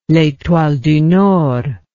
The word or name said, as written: letoile du nord